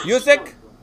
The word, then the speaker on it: Usyk: Is that how you pronounce it? – Usyk. – Yeah, Usyk. And no lie, I didn't see the fight.